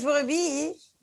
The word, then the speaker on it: brebis: Que no, que es brebí.